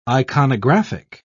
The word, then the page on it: iconographic: British English